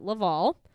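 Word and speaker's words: Le-Vel: So Shanann actually worked for a company which is definitely supposed to be pronounced Level, but they're fancy and they pronounce it Laval.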